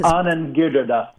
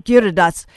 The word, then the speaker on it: Giridharadas: Well, I love how your evaluation and with Anand Giridharadas, I can never pronounce it correctly. And his Anand Giridharadas. – Giridharadas.